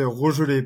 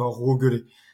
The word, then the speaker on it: Rogelet: Alors je, corrige juste euh, mon pseudo sur les réseaux, c'est Télégor, mais mon nom c'est Rogelé, pas Rogelé. Donc euh, le G euh, se prononce bien G, Richard.